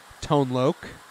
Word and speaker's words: Tone Loc: Tone Loc, as I've heard that that's pronounced. – There you go.